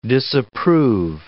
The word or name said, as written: disapprove